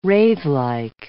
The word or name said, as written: wraithlike